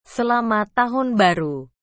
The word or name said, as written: selamat tahun baru